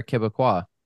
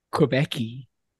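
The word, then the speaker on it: Québécois: Yeah, so it's Quebec, and then the people there are Quebecois. – I would say Quebec-y.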